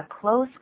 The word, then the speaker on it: close: 还有一个 What a close call. – 都可以这样讲，这是蛮实用的。但是我们现在有一点时间谈这么一个字的发音。 Close 是代表很靠近的意思。如果是咋把这个字念成 close, 这什么意思啊？ – 就是关门的关。